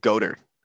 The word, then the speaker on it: Goedert: Is it Goddard or is it Goedert? – I think it's Goddard. – Just forget the silent E.